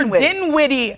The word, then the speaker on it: Dinwiddie: Spencer Dinwiddie.